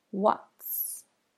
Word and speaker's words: what's: Now, make sure you get the T for what in there. It's a stop. What's. Not just an S sound.